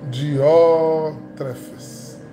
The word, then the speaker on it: Diótrefes: Versículo 9 serviam uma palavra à igreja, mas Dióstrefes, Dióstrefes, Diófre, eita gente, a pronúncia é Diótrefes, porque em português fica Diótrefes, mas em grego é Diófrestes, homem ambicioso de poder, não nos quer receber.